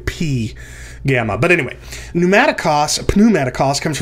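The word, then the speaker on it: P (π): you're supposed to pronounce the P. And by the way, it is pronounced P, not pi.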